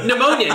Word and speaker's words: pneumonia: Pneumonia. Now it's spelling night. Can you spell pneumonia? And no, there's a silent P, Billy.